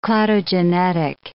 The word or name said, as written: cladogenetic